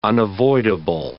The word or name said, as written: unavoidable